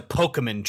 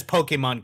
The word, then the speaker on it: Pokémon: And then at the beginning of this video, she mispronounces Pokemon. She says, oh, he's a Pokemon trainer. And awesome. Later in the VHS, she says Pokemon correctly. So they clearly were like, it's actually Pokemon, but they didn't go back and recorrect.